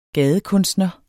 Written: gadekunstner

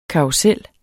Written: karrusel

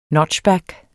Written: notchback

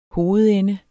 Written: hovedende

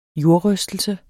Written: jordrystelse

